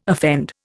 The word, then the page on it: offend: Indian